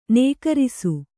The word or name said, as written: ನಕಾರಿಸು